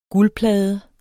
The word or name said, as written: guldplade